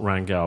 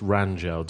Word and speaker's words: Rangel: Well, no, but apparently it's not Rangel. Apparently, also, it's Rangel. Did you know this? It's Angel Rangel. – Who knew? – Pronounced like a J, apparently.